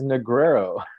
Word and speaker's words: Negreiro: David, just so our viewers can know, how do we actually say your last name? – Yeah, my last name is Negreiro. – Okay. – It is a not easy one to pronounce.